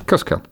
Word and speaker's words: Cuscal: Have I said that right? – Cuscal, yeah. – Cuscal.